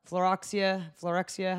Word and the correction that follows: phylloxera: how do you say it? – Phylloxera, I don't know.